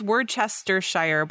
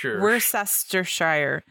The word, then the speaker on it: Worcestershire: Anyway, I think it's Worcestershire, but not with a ch sound. – Worcestershire.